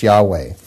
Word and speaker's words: Yahweh: And whenever we see the word Lord in all caps like this, it is, in the Old Covenant, it is the word YHWH, which many will say is pronounced Yahweh.